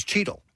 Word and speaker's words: Cheadle: Now, Cheetle, Alberta is spelled C-H-E-A-D-L-E. – Not like Don Cheetle. – But Cheetle from Cheetos is C-H-E-E-T-L-E.